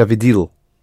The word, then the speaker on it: видел: Я видел. – Видел, stress the first syllable.